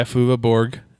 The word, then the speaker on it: Flula Borg: Falua Borg as Javelin. – Yeah, Flula Borg.